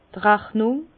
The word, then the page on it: d' Rachnung: Alsatian; Bas-Rhin; Herrlisheim